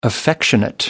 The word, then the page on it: affectionate: American English